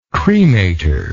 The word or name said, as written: cremator